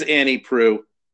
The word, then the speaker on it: Annie Proulx: For the record, the correct pronunciation, we'll try to get it right as we go through this. It's funny that we keep screwing it up, is Annie Proulx.